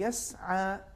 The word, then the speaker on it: یسعا: دیکھیں مثال کے طور پر آیت نمبر 8 میں لفظ یسعا آیا ہے، اس کا صحیح تلفظ یہی یسعا، اگر ہم کہیں گے یسعا یہ تفخیم ہو جائے گا.